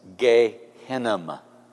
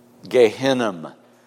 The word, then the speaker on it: Gehinnom: Gehenna describes what the Hebrew would have known as Gehinnom. Gehinnom.